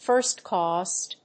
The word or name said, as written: first cost